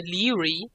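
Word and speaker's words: Laoghaire: Ja, das ist das wie, wie bei Outlander, da heißt eine Leary ausgesprochen. Geschrieben wird es mhm. Longhair.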